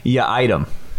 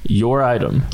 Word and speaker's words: Yiadom: No, like you're leaving the supermarket and like, can't you forgot your item? Like, that's how you say it. Your item. – Not your item. Not my item.